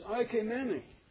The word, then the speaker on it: oikoumene: And its pronunciation is oikoumene. I use it because you may sense a similarity in sound to the term ecumenical.